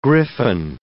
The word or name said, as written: griffin